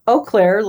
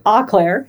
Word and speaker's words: Eau Claire: Not Eau Claire, like how it's spelled and how they say it in Wisconsin, but we say Eau Claire in Michigan.